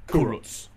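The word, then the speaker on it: Kurucs: Say Kuru. – Kuru. – Roots. – Roots. – Kuruts. – Yeah. Kuruts.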